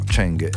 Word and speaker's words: Zeng: Tanto sta per chiudere la partita Lorenzo Musetti, in questo momento serve l'americano di origine asiatica, Cheng, che si scrive Zeng, però Cheng è la pronuncia, e ci sono due match point per Musetti.